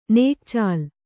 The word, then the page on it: ನಗೆಚೆಲ್ಲು: kannada